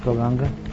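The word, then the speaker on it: Gauranga: All right, Golanga. Lord Chaitanya, who has golden limbs. Here's your card. So you can remember the accent is on Golanga.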